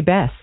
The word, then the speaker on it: Bess: It's Kay Bess.